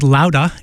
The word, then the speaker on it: Louda: Brand new from the Amplifier Head, Sal Baglio's new band called Louda, L-O-U-D-A-H. It's supposed to be pronounced in Bostonese, and it is.